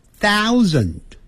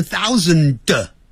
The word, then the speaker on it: thousand: 那一千呢，那就是 1000 1000，你看你有 th 开头，所以舌尖要吐出来啊，然后上齿下齿咬住 thou ou 念成 ow， thousand thousand thousand，那个的不要念成 thousand，那个的要念出来的时候你把它切住 thousand，很轻的这样点一下，或甚至把它音切住，不让他能念出来 thousand 也对， hundred 也对。